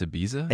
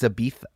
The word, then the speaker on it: Ibiza: Is Ibiza how you pronounce Ibiza? – It's Ibiza.